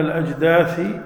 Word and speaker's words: الأجداث: فإذا هم من الأجداث إلى ربهم ينسلون. الأجداث هذه تقال بالثاء وبالفاء في قراءة، لكن ما أظن أنها متواترة. الأجداث، وإذا عرفنا أن الأجداث تنطق بالثاء والفاء أيضا نستفيد فائدتين.